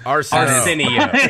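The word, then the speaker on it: Arceneaux: Damn, such a great intro to mispronounce my last name. – It's Arsenio. – Yeah. Arsenio Hall. – It is. That's closer, actually. It's Arsenio. You were close.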